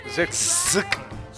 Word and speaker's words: Zetkin: Zetkin? Zetkin? – Zetkin.